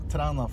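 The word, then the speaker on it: Træna: Yeah, yeah it's, uh, it's very good, but it's it's pronounced Trana Festival. Trana. Trana Festival. Uh-huh. – I don't think I don't know if you know the letter A. No. Uh, it's one of those special letters we have in uh, in Norwegian, which ah. it's A like in uh, an A and E combined.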